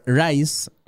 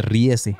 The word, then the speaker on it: Riese: Las famosas instalaciones subterráneas del Der Rice, si es que así se pronuncia. Donde Rice, no, pero dice Riese.